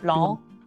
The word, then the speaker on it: Blanc: Is there an E at the end of that, or is it just a C? – Just a C. – Okay, so don't pronounce that. Just Blanc.